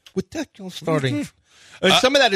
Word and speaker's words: Deculus: But the Texans started to cool us. – Deculus.